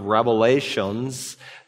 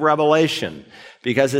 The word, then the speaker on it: Revelation: It's not the book of Revelations. There's no S on it. It's the book of Revelation because it's a single revelation that God gave through his son to the apostle John and he wrote down for us, his bondservants.